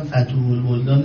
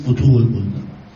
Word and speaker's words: فتوح البلدان: اولا فتوح البلدان نیست، فتوح البلدان.